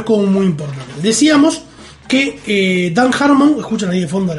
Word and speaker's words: Dan Harmon: ¿dije Dan Harmon? ¿Lo dije bien? – Dan Harmon, sí.